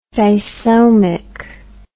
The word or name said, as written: disomy